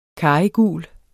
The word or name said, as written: karrygul